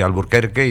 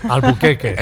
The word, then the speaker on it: Alburquerque: Y entonces escribieron Alburquerque en la parada de la estación. Como lo pronunciaban, como no eran capaces de pronunciar la R, se le cayó. – Alburquerque. – Alburquerque.